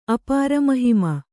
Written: ಅಪಾರಮಹಿಮ

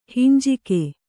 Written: ಹಂಜಕ

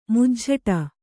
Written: ಮುಜ್ಝಟ

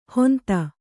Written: ಹೊಂತ

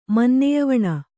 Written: ಮನ್ನೆಯವಣ

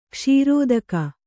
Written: ಕೌಸೃತಿಕ

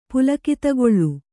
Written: ಪುಲಕಿತಗೊಳ್ಳು

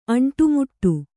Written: ಅಂಟುಮುಟ್ಟು

